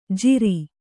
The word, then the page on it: ಜಿರಿ: kannada